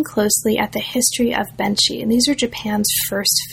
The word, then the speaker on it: Benshi: focuses mainly on Benshi. Am I pronouncing that correctly? – Yes. – Benshi, great.